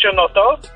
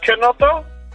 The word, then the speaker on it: Chinotto: It's actually not pronounced Ginotto. It's Canotto. It's CH in Italian is K sound, right? – I could have told him that.